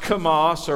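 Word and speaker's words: Kamas (חמס): It is pronounced Kamas, or with a K, Kamas. And as Matt said, you have to get a little phlegm in your throat to get it right, Kamas.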